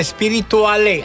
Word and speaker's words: Spiritualé: La coppia Biagio Antonacci Safe con Spirituale.